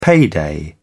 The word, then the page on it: payday: British English